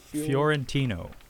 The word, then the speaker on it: Fiorentino: Fiorentino. – Fiorentino.